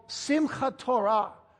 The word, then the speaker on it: Simchat Torah: It was a holiday added by the rabbis later on called Simchat Torah. Simchat Torah. If you want to know how to spell it, it's S-I-M-C-H-A-T. Simchat. Simchat. S-I-M-C-H-A-T. Simchat Torah. Running together, it's Simchat Torah.